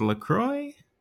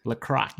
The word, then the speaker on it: LaCroix: It's pronounced LaCroix. – LaCroix. – There's an accent. Yeah.